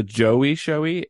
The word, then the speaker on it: Joie Showy: came out called The Joie Showy. It's spelled J O I E. Then Showy is S H O W I E. The Joie Showy.